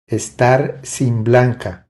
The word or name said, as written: Estar sin blanca